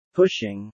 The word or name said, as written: pushing